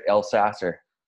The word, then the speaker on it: Elsasser: I'm very honored, very lucky to have Johnny, and I hope I'm saying this right, El Sasser. – Yeah, perfectly.